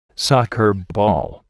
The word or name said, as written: soccer ball